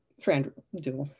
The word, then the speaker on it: Thranduil: Thranduil, I can't talk today. Doesn't matter. I'm getting the good. Yes, I said it right.